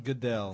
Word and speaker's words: Goodell: Yeah, Tuesday, someone hacked the NFL's Twitter account and announced that Commissioner Roger Goodell, am I pronouncing it right? – Goodell.